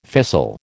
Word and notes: fissile: American English

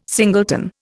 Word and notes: singleton: Indian

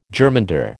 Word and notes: germander: American English

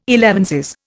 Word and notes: elevenses: Indian

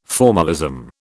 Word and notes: formalism: British English

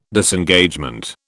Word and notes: Disengagement: British English

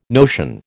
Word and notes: notion: American English

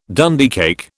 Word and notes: Dundee cake: British English

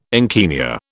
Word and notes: encaenia: British English

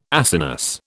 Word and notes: Acinus: British English

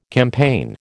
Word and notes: campaign: American English

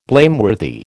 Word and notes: blameworthy: American English

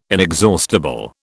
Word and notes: inexhaustible: British English

